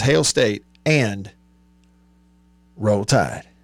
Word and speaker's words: Roll Tide: Now, I know a lot of them who they don't pronounce the L's. They just say Row. Row Tide. Row Tide.